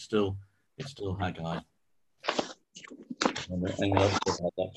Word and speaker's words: Haggai: so that's Haggai, which really does convince me it's only two syllables.